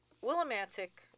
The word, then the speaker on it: Willimantic: So, Willimantic happens to rhyme with the word romantic.